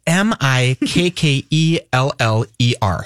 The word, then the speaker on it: Mikkeller: Um, first of all, even to pronounce the name of your beer, it's M I K K E L L E R. Sure. – So Mikkeller.